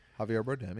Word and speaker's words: Javier Bardem: I think Javier Bardem, I don't know if I'm saying it right. – Javier Bardem, yeah.